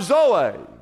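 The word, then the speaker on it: Zoe (ζωή): It says, I've come to give you, and He uses the word Zoe, Z-O-E, but it's pronounced Zoe.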